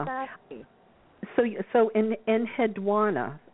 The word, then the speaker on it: Enheduanna: I would say your pronunciation isn't any more right than mine. Mine is Enheduanna. But you pronounce the U very specifically, and I don't see why you either would be right or wrong, if you know what I mean.